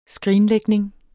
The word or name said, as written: skrinlægning